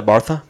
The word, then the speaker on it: Barcelona: I did the Bartha because that's how they say it.